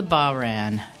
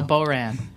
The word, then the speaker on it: Bahrain: a little bit of the Bahrain. Did I pronounce that correct this time? – Not really. – No, Bahrain. – Bahrain.